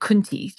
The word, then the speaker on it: Kunti: There is a Hindu goddess, I'm going to pronounce it Kunti, just so you know, K-U-N-T-I, or the great Yoni of the universe, who's sort of been referenced in Hindu texts really between 200 and 400 BC.